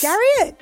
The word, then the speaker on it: Garriott: Oh, and his last name rhymes with Harriet. – Yes. – Garriott.